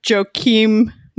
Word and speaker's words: Joakim: Off the bench, Jamichael Green, Patrick Beverley, Joakim Noah.